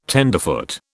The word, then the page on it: tenderfoot: British English